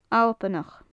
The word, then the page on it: Albannach: Gaelic